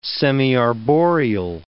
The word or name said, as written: semiarboreal